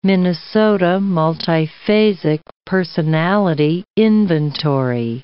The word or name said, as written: Minnesota Multiphasic Personality Inventory